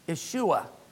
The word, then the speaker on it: Yeshua: What is the Hebrew pronunciation for Joshua? – Yeshua.